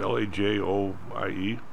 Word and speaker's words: Lajoie: How do you pronounce it? LaJoie. – I think that's correct. Yes.